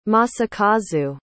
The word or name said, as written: Masakazu